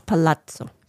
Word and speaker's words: palazzo: reluctantly attends a seance at a decaying haunted palazzo. Is it palazzo or palazzo? – It's palazzo.